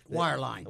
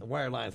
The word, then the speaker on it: wireline: I mean, we are going from the days when almost all of our phone work and communication work was wireland. Did I say it right this time? – Wireline. – Wireline. Thanks, man.